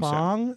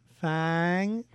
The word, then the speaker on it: FAANG: How do you pronounce a double A? – I would think it would be elongated, wouldn't it? – I would imagine Fong. – So. Fong. – Fang.